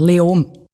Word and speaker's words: Leoben: Spricht man es Löben aus? Wie spricht man das überhaupt aus? – Eigentlich fast kein Konsonant. Leom.